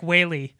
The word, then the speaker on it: Whaley: Frank Whaley. It's spelled like whale with a Y at the end.